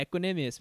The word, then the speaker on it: Equanimeous: Is it Equanimeous? – Yeah, Equanimeous, man.